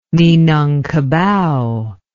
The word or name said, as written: minangkabau